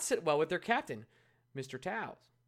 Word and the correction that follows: Toews: Towns. What am I doing? What am I doing? – How do you say his name again? – Toes. Jonathan Towns.